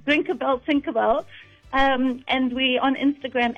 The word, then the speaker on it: Drinkerbell: So there's a website, Drinkabell, but it's spelled DRINKERBELL, Drinkabell.